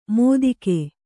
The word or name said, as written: ಮೋದಿಕೆ